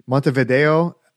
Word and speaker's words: Montevideo: and hopefully I'm not butchering it, Montevideo of Uruguay. – Excellent.